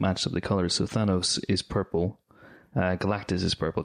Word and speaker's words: Thanos: Thanos. Is the way that it is being pronounced.